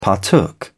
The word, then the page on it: partook: British English